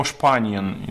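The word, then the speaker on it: Spanien: Например, я часто встречаю, в немецком языке есть такая особенность, что если слово начинается с буквы S, ну, которая пишется как английская буква S, но читается как «ше». Ну, яркий пример, это, не знаю, есть слово «шпаниен», там, «Испания», есть слово «шпаниель», то, что мы называем «спаниель».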